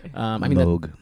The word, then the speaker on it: Moog: Moog. It's pronounced Moog.